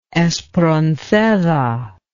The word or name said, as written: Espronceda